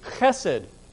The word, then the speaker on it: chesed: The first word, His merciful kindness, is the Hebrew word chesed. Chesed. And my first Hebrew professor said, if you pronounce that first letter correctly, the person sitting in front of you feels something hit the back of their head, you know.